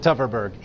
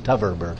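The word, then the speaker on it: Tverberg: But I do know she pronounces it Teverberg, even though it's TV, it begins TV, but it's pronounced Teverberg.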